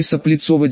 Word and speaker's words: Соплицово: Первое Соплицово 19, в оригинале это покинутое гнездо лютов назывался Соплицово, с ударением на предпоследний слог.